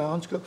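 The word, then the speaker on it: Hancko: En uh, Hansko, nou zeg ik het goed, ja, Hansko.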